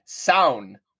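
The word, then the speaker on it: Samhain: But tell me if you can pronounce this word. It's S-A-M-H-A-I-N. – Gosh, I don't. Give me the spelling one more time. – S-A-M, like mom, H-A-I-N. – Samhanophobia? – No, this is how you pronounce that word I just said. Saun.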